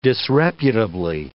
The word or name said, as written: disreputably